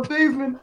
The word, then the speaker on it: Theismann: I'm saying Joe Theismann because that's his actual name. I know people think I'm saying it wrong. You can Google it. He didn't win the Heisman. – We call him Theismann. – Okay. – Theismann. Not Theismann. Theismann. Stop crying.